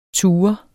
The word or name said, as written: TUREDES